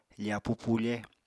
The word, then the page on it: fapu pufe: apatani